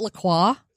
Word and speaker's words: LaCroix: LaCroix. I call it LaCroix. – Oh, God. – Because, I will fight you. – Because that's how it's fucking pronounced.